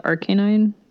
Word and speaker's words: Arcanine: I kind of did a mix of both. I just call it Arcanine. So you still have the long A, but the emphasis is on the first syllable.